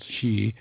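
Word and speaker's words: shi (四): The number four, for example, pronounced shi, can also mean death.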